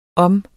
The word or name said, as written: om